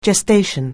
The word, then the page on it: gestation: anglais